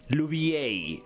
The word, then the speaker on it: Louvier: Lubier.